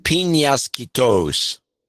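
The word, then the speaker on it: Peñasquitos: It's pronounced piñasquitos.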